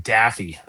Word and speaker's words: Daffue: I had to listen to the broadcast just to come up with, how do you pronounce D-A-F-F-U-E, MJ Daffy. At least that's the way the guys were laying it out there.